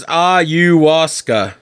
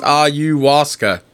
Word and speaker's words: ayahuasca: What is ayahuasca? – Here's what I found. – Hey, Siri. How do you pronounce ayahuasca? – Here's what I found.